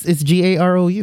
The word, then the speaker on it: Garou: I pronounced it Gru. Like Despicable Me. – Oh, it's Garou, right?